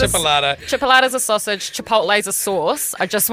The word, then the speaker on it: Chipotle: I've learned that Chipotle was is pronounced Chipotle. Was that it? No. – Yeah, it's Chipotle. – Chipotle, yeah. – It's not Chipolata, it's Chipotle, and they're two different things.